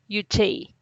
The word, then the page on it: yu’ txey: Teotitlán del Valle Zapotec